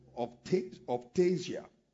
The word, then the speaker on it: optasia: Then number three, there are open visions that are described with the Greek word optasia. That's O-P-T-A-S-I-A. Optasia. And optasia means visuality. Optasia is spelled as O-P-T-A-S-I-A. Where you know, that sounds very similar to optics.